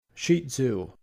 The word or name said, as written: Shih Tzu